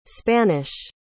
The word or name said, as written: spanish